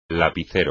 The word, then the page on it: lapicero: espagnol